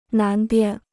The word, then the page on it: 南边: nán bian